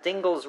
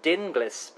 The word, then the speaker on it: Dingles: Is it Dingles rhymes with shingles? Or is it, as you've said, rightly so, I believe, Dinglis?